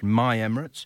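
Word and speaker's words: MI Emirates: I understand that should be pronounced My Emirates in the UAE 2020 League.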